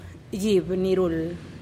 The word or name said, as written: gib-nirul